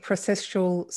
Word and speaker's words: processual: Your theory, and I'm probably not pronouncing it, processual soul theory, or your soul theory, do you mind explaining that a little bit more? And I probably pronounced that incorrectly. – No, you pronounced it exactly correctly.